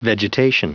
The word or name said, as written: vegetation